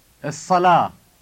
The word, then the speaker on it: الصلاة: The prayer would be As-salah. – With a double hollow sounding sa.